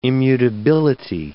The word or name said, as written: immutability